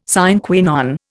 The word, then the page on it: sine qua non: Indian